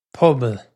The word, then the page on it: pobal: Irish